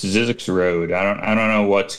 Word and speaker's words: Zzyzx: Zizzix is a town outside Las Vegas. It is spelled Z-Z-Y-Z-X.